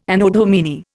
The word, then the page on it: Anno Domini: Indian